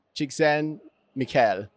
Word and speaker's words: Csikszentmihalyi: And it's actually pronounced Mikhail Csikszentmihalyi.